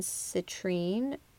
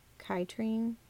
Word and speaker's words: citrine: And then smoky quartz and citrine. I think I'm saying that right. Or chitrine? – Citrine. – Not sure. Citrine.